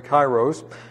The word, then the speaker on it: kairos: And this, I learned this AI here as a diphthong, and has the I sound. That's why I say kairos.